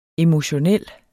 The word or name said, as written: emotionel